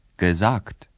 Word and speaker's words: gesagt: Sagen, gesagt.